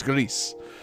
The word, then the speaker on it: Gris: It's not Grease starring John Travolta. No, not at all. It's Grease. Little L on that R, and you definitely pronounce the S.